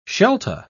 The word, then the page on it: shelta: British English